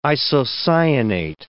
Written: isocyanate

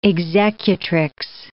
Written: executrix

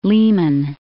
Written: Lehman Caves

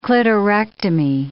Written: clitorectomy

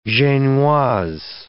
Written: genoise

